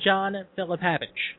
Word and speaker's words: John Philapavage: Raven's there, Perry Saturn's there, Kevin Kiernan is there, and John Philapavage is there as well.